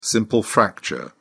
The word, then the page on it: simple fracture: British English